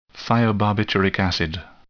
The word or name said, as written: thiobarbituric acid